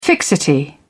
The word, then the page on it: fixity: British English